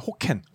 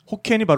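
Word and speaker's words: Hokkien: 중에서 굉장히 많은 수를 점하고 있는 게 호켄. 호켄이 바로 복권이라는 그렇죠. – 그 발음을 이제 그쪽에서 그렇게 하는 거거든요.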